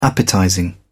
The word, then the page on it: appetizing: British English